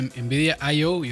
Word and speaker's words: Nvidia IO: Pero este, una de las cosas que me llamó la atención a mí fue este Nvidia IO, y ahora sí lo dije bien,